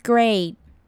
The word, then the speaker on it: great: 首先看到 great 这个字， great 呢之间的 ea 是发 a 的音， great, great.